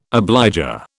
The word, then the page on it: obligor: British English